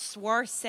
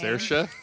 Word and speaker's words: Saoirse: What about Swarce? I know that's not how you say her name. – Saoirse? Saoirse Ronan? – I know that's the Ibis.